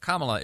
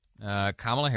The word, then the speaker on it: Kamala: Kamala is her name. Kamala Harris is how it's pronounced,